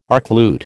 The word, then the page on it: archlute: American English